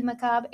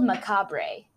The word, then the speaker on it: macabre: and that's how you say macabre. – Oh, my bad. there was an R in it. I was confused. – Yeah, it's spelled macabre. – Yeah. – But it's macabre.